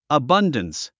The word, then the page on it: abundance: əbʌ́ndəns; アバンダンス